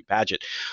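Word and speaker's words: Bagehot: How do the English pronounce his last name? I'm sorry. I'm going to as a Bagot. – So there, I as an American have just showed how American I am. I've been referencing him for decades, and I've never once pronounced his name correctly.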